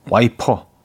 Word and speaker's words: 와이퍼: 달달치연이 와이프 한 번에 추억이 왔다가 다시 흘러가요. 아, 와이퍼. 와이프 아니죠. 와이퍼죠.